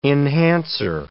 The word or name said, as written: enhancer